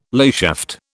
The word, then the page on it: layshaft: British English